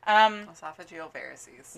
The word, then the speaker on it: esophageal varices: Esophageal varices. – Yeah, weird. Thank you for saying that correctly.